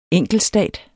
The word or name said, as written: enkeltstat